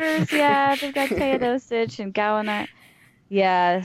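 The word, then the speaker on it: Teodosić: I think it's pronounced Teodosic, but Teodosic, yeah.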